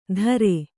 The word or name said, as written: ದರೆ